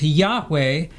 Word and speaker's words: Yahweh: It says, when God first entered into a covenant relationship with Abram, he made himself known to the patriarch under the name YHWH, pronounced Yahweh, and translated as L-O-R-D in capitals in the King James Version, also in the New King James Version.